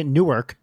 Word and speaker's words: Newark: Newark. – Well, and I'm not kidding. That is how you say that town in New Jersey. You don't say Newark. You say Newark.